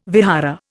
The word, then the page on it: vihara: Indian